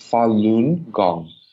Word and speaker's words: Falun Gong: It's pronounced Falun Gong, also known as Falun Dafa.